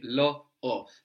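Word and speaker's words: l'ho: So it sounds just like it lo by itself, no? Lo. But it's actually lo o.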